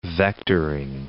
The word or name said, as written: vectoring